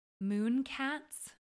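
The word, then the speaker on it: Münecat: I will start, and I don't think I've mentioned this before, but I have been consuming, I think it's pronounced Mooncat's YouTube channel, M-U with an umlaut, N-E, cat is her channel.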